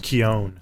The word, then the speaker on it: Keoghan: Do you guys know who Barry Keoghan is? – Keon. Yes. It's pronounced Keon.